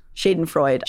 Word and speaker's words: Schadenfreude: While I don't condone doxing, you know that Schadenfreude, how do you say that? – Schadenfreude. – I always say that wrong. Schadenfreude.